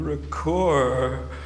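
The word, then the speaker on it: Ricœur: I've been really turned on by Ricoeur's recent thinking on linguistic hospitality. And what about his name, Ricoeur? Gosh, I should really lean into that second syllable, because God, he comes from the heart.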